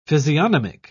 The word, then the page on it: physiognomic: British English